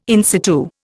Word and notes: in situ: Indian

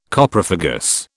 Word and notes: Coprophagous: British English

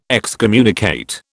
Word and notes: excommunicate: British English

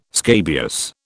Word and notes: scabious: British English

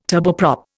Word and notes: turboprop: Indian

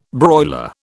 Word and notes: broiler: British English

